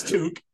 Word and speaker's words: toque: That's good pronunciation of Toque, by the way. – Yeah, I'm close to Canada, so there you go. – We're fairly close to Canada, so I know it's Toque. – I know everybody says Toque. Right. Well, everybody in America usually.